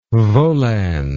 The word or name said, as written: volans